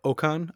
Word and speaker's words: Ocon: Every time I see Pierre also, I like, especially when he's standing next to Ocon, how we want to say it correctly here.